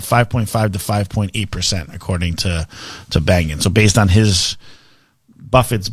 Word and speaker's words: Bengen: So Bill Bengen, I believe is how you pronounce his name, is the father of the 4% rule, right? Is that how I remember pronouncing his name properly? – Mm-hmm.